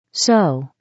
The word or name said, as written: sew